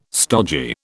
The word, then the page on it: stodgy: British English